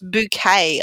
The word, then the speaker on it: Bucket: Hyacinth Bucket, pronounced bouquet, I understand, or that's how she pronounces it because she's all posh-like.